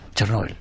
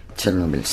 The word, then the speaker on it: Chernóbil: ¿Chernóbil? ¿Cómo se dice? ¿Chernóbil o Chernóbil? – Chernóbil. – Chernóbil, sí.